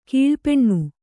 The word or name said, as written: ಕಲ್ಪನೆ